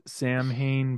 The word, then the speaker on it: Samhain: Spelled Samhain, but pronounced Samhain.